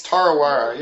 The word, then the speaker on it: Tarawera: And the first is a tarawera. Am I saying it right, tarawera? – It's tarawera, yep.